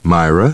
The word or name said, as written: Myra